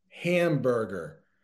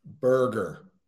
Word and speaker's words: hamburger: Hamburger. I see a lot of people trying to pronounce it as a U. We actually pronounce it as an E. So hamburger, right? Burger, not booger.